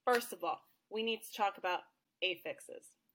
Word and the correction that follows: affixes: Affixes, LOL. Affixes, thank you. I put the wrong emphasis on the wrong affixes.